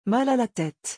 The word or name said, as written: mal a la tete